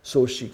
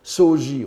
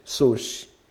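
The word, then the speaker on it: Soshi (荘子): Soshi, qu'on prononçait souvent Soji autrefois, et maintenant la mode sinologique japonaise tend à prononcer Soshi. Je préfère Soji pour ma part, mais suivons la mode.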